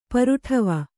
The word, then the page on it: ಪರುಟವ: paruṭava